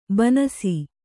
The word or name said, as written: ಬನಸಿ